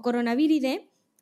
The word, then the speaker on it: Coronaviridae: La familia de coronaviridae, ¿ok? O Coronaviride, que es como se debería pronunciar, es enorme, enorme.